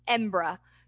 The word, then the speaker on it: Edinburgh: Plus, if you pronounce Edinburgh like a Scotsman does, it's pronounced Embra.